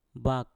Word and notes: bak: Mpur

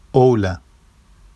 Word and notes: owla: Vafsi

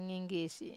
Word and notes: nginggisi: wamesa

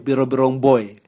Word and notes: bwirobwirong boe: Raga

